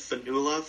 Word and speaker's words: Fionnuala: And although maybe there's somewhat of a chance Fionnuala Flanagan, that's how she pronounces her name. I heard her say it. – Oh, okay. – I originally thought it was Fionnuala before I heard her say it, but she's Irish, so that's why it's spelled and pronounced like that.